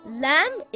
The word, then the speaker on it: Lam: Lam is always pronounced light, except in the name of Allah, if it has a fatha or a dhamma on the word before.